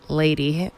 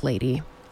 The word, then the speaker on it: LVDY: Beyond the Groove Productions and the Telluride Mountain Village Owners Association present Music on the Green, featuring live music from Lady, pronounced Lady. Well, it's spelled L-V-D-Y, but it's pronounced Lady.